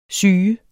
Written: syge

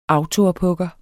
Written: autoophugger

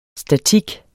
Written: statik

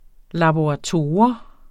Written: laborator

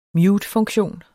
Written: mutefunktion

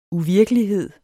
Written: uvirkelighed

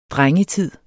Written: DRENGETIDEN

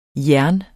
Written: jern

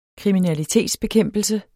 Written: kriminalitetsbekæmpelse

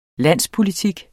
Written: landspolitik

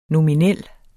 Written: nominel

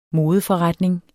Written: modeforretning

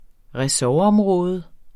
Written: ressortområde

